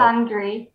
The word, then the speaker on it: misandry: No, I'm sure it's misandry. Like misogynist. – Yeah. – Misandry, I would stress the same syllable, right? – Yeah.